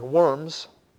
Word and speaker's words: Worms: Worms, which when we look at it in the English, looks like worms, but in the German, W is pronounced with a V. So it's Worms.